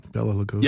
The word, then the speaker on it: Bela Lugosi: I said that right? Bela Lugosi. – Yep, that's correct.